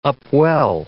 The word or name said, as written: upwell